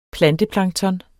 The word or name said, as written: PLANTEPLANKTON